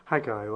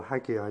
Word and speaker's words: Haggai: Now, just over 2,500 years ago, there's a Jewish prophet by the name of Haggai, or Haggai, depends how you want to pronounce it.